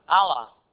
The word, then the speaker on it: Allah: But contained in the name Elohim is a Semitic name, I don't know how you pronounce that. Allah, what? Allah. Yeah, there you go.